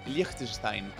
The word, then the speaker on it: Liechtenstein: Liechtenstein.